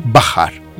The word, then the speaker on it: baħħar: Baħħar, baħħar. L-aħħar sillaba fin-nom baħħar hija twila, dik hija differenza.